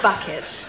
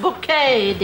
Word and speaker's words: bouquet: Oh, yes, we have a bucket. Pronounced bouquet, dear.